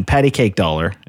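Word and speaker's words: Patti Cake$: Patty Cake Dollar is how it would actually be pronounced in English.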